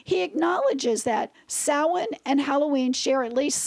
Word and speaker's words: Samhain: I always thought it was Samhain. Yeah. It's pronounced Samhain.